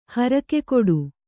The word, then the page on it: ಹರಕೆಕಟ್ಟು: harake kaṭṭu